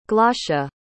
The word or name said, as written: glaucia